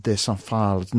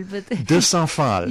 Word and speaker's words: de Saint-Phalle: It's De Saint-Phalle. – De Saint-Phalle. – Yeah. De Saint-Phalle. Saint-Phalle is in America too, and it's pronounced with an American accent.